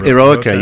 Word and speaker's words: Eroica: 3 Eroica, are we saying that correctly? – Eroica, yes, heroic, which of course gives the whole program its kind of tagline, heroic Beethoven.